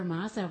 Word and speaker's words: Mazza: Well, we have Heather Mazza with us also.